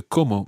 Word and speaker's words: como: No, the word como meaning how and the word como meaning I eat sound exactly the same. There is absolutely no difference in pronunciation.